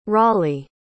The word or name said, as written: Raleigh